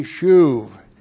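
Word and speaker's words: shuv (שוב): S-H long U-B shuv.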